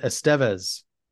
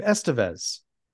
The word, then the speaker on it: Estevez: And Rob Lowe referred to their last name as, he said, Estevez. I've always said Emilio Estevez, but he was saying yeah. Estevez. So I've been saying it wrong all these years because he said at the time, Charlie was still going by Charlie Estevez back in like the early to mid 80s.